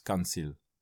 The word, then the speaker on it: Kantjil: Spreekt uit als Kanjil, Spaans, een soort van Spaanse uitspraak.